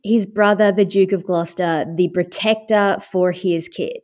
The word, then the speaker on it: Gloucester: Is it Gloucester? – Yeah, Gloucester. Yep. – Gloucester.